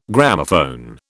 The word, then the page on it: Gramophone: British English